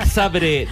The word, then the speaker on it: Zabrze: ¿Por qué sabe que es Zabrze? ¿Por qué pronuncias porque lo sé. – Zabrze? – Porque es así.